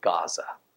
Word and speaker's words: Gaza: By the way, that Azah is pronounced in our modern language as Gaza, the same Gaza that we're dealing with, that Israel's dealing with today.